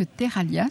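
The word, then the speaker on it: Terralha: Et vous m'avez expliqué que Terralia, ça voulait dire argile en occitan. – Oui, alors Terralia, ça s'écrit LH comme grouillé.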